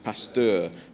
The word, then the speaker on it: Pasteur: Spontaneous generation, that life arose from non-living matter, was scientifically disproved 120 years ago by Louis Pasteur," I can't say his name, "Pasteur," thank you, "and others."